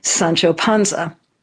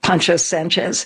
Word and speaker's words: Sancho Panza: So according to her, this guy friend said, "You look just like Sancho Panza." Except for he mispronounced it and said Pancho Sanchez.